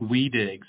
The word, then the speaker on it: Wedig: Yeah, it's spelled like Weedig. So W-E-D-I-G. – E-I-G. W-E-D-I-G.